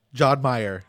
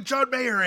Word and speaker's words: Mayer: I know Did he say Meyer? – Yes. John Meyer. – Oh, man. – And then he kind of like, I remember like he kind of did like a. – I think he made a face. – Yeah, like he made a face like he knew I mispronounced that. I was like, Jack Black, how do you not know who John Mayer is?